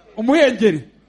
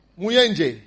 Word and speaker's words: umuhengeri: How do you say waves? – Umuhengeri. – Muhengeri. – Umuhengeri.